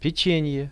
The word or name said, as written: pechenie